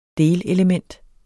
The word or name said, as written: delelement